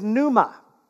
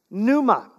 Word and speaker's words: pneuma: The New Testament word for Spirit is pneuma. Pneuma. It's got a silent P.